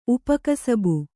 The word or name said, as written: ಉಪಕಸಬು